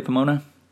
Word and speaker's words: Pomona: Am I pronouncing that correctly, Pomona? – That's right. Yeah, right.